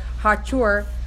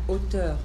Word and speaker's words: hauteur: And hills, referring to the klipot, which are compared to hills by reason of their hachur. Did I pronounce that right? It's a French word, isn't it? How do you pronounce that? – Hauteur.